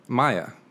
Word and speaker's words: Miah: My name is Maya. I don't know why it's such a challenge. Well, when you spell it M-I-A-H, that could go a whole number of different ways.